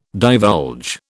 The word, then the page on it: divulge: British English